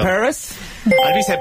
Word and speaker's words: Alvise: Al Baize. – Eso es correcto. Bastante mal pronunciado, pero es correcto. Alvise Pérez.